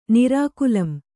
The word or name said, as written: ನಿರಾಕುಲಂ